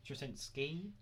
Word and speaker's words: Straczynski: Straczynski.